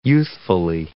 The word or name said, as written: youthfully